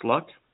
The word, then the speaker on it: Lugh: They believe they may be a modern incarnation of the Euro-Celtic god Luch, and that's spelled L-U-G-H, but it's pronounced Luck.